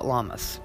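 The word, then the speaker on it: Lammas: Some people call it Llamas, which sounds like llamas, and it's spelled the same except for it has one L and two M's instead. It is August 1st this year, and it is a time of hope and fear.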